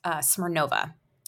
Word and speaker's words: Smirnova: My name is spelled or pronounced Smirnova. Michelle Smirnova, but I'm really excited to talk with you today.